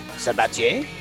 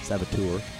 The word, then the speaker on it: Sabatier: If he is French or French Canadian, it would be pronounced Sabatier. Could also be Sabatier if he's not French. – Saboteur. – No, you're wrong. I can say without equivocation, it's not Saboteur.